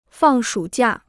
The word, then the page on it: 放暑假: fàng shǔ jià